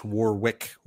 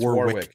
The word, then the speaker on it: Warwick: Mispronouncing. It's Warwick. It's Warwick Davis.